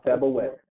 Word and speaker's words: Sabaoth: So you want Jehovah, Sabaoth, the Lord of hosts, Jehovah Sabaoth, like Sabbath. – Spell that for Sabaoth. S-A-B-A-T-H. – S-A-O-T-H, yep.